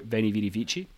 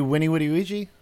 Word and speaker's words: Veni, vidi, vici: Wouldn't it be Winnie, Woody, Ouija? Something like that? – Exactly. Yeah. Because it's pronounced with a W, not with a V, because W doesn't exist in that language, in Latin.